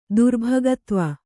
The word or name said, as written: ದುರ್ಭಗತ್ವ